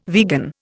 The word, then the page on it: vegan: Indian